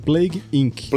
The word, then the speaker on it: Plague Inc.: Primeiro jogo que eu vou indicar é o Plague In. Eu nem sei se é assim que se pronuncia. Como é que se pronuncia, galera, esse jogo? – Plague Inc.